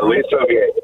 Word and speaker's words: Aliso Viejo: Aliso Viejo, California. Am I saying that right? – Aliso Viejo. That's correct.